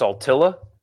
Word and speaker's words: Saltillo: Saltillo is pronounced Saltilla. Is it? I've only heard Saltillo.